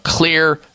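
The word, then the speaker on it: Xlear: And clear is spelled with an X. X-L-E-A-R.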